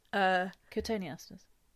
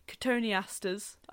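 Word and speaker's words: cotoneasters: I read my notes so badly then, and I said to myself the other day, you must remember to say that correctly. Cotoniasters. I didn't mean cetoniaster.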